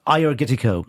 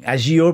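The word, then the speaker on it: Agiorgitiko: The grape is Ayurgetico, or Ayurgetico, if you were to pronounce it as you'd read it in English, but you don't pronounce the G-I, apparently.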